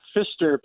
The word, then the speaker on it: Pfister: Dr. Kurt, K-U-R-T, Pfister, P-F, I-S-T-E-R, the Pfeiffer and all the P-Fs. The German, you know, pronounces it with just a straight F.